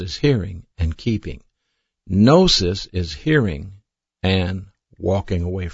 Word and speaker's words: gnosis: One of those words is spelled G-N-O-S-I-S, and it's pronounced gnosis. The other word is spelled E-P-I-G-N-O-S-I-S.